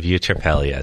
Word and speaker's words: eutrapelia: And it's called The Virtue of Eutrapelia.